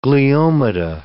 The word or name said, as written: gliomata